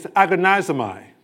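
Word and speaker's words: agonizomai (ἀγωνίζομαι): The Greek word strive is agonizomai.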